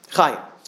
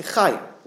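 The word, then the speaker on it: chayim (חיים): Chaim. She pointed out, notice that it's plural. Chaim is a plural word. Okay, it's not chai, you say chayim, lechayim.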